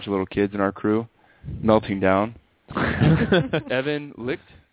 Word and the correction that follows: Licht: is that how you say his name? – Sure. – Dominating performance. – Light. – Light? – Light, I have here. Evan Light.